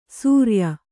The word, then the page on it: ಸೂರ್ಯ: Kannada